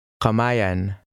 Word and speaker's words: Kamay: Kamayan. Kamayan. – When the letter combination AY appears at the end of a word or a syllable, it sounds something like I.